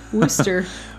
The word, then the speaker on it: Worcestershire: At least you say that right. – Worcestershire. – Worcester. Worcestershire. Where I come from.